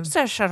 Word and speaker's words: Saoirse: Saoirse Ronan.